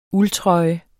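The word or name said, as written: ULDTRØJE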